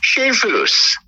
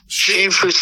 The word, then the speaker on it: Schoenfuss: Schoenfuss. Schoenfuss phonetically. It's spelled S-C-H-O-E-N-F-U-S-S.